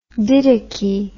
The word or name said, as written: didache